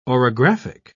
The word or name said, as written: orographic